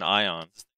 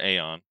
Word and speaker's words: Aion: There was this thing in Ion, or Aeon, however you want to pronounce it, A-I-O-N, called Dredgian.